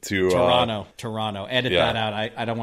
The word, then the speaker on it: Toronto: Toronto. Wait a minute. Toronto. I can't believe I pronounced the T. I should get docked major points for that.